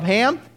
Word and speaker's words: Ham: Most of us haven't met yet, and my name is Rob Ham, and the last name is spelled H-A-M.